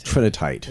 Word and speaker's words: Trinitite: Trinitite.